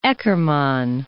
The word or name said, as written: Eckermann